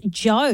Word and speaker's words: Djo: Onto an artist that went from acting to music, and that is Joe, D-J-O it's spelled.